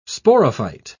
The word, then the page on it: sporophyte: British English